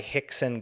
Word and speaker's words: Rickson: Rickson Gracie. Rickson is spelled R-I-C-K-S-O-N. He's a Brazilian guy, so the R's turn into H's for that.